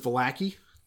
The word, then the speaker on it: Vallaki: So so the the, the point is that I guess the American pronunciation is Vallaki. I, I uh, at least the Midwestern one.